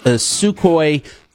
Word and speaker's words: Sukhoi: And then this is from the Jewish press, Russia to supply Iran with advanced Sukhoi 35, if I pronounced that correctly. – Yes.